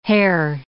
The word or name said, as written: Herr